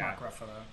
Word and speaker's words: Ruffalo: Mark Ruffalo.